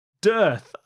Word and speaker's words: dearth: So you pronounced that Dearth? I thought that was one of the ones that was Darth, or is that like Hearth? – Yeah, you're thinking of Hearth.